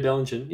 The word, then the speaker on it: Bellingen: So, you know, I'm like, but they just moved to Bellingen. Is that how you say it? – Oh, yeah. Yeah, Bellingen.